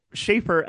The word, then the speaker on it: Shafer: Because, like, Shafer, S-H-A-F-E-R, is how my name's spelled.